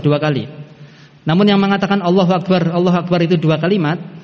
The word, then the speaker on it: Allahu Akbar: Sehingga dibacanya enggak berhenti, bukan Allahu Akbar. Allahu Akbar, nah, berhenti kan? Bacanya Allahu Akbar, Allahu Akbar. Bersambung sehingga dikatakan ganjil.